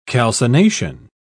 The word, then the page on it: calcination: British English